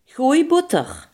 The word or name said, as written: roomboter